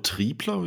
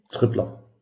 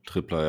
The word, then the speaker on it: Trippler: Genau, vom europäischen Markt ist das quasi der Oliver Trippler oder Triebler, wie du ausgesprochen? – Trippler. – Trippler, ja, genau, von den Copenhagen Towers aus Dänemark.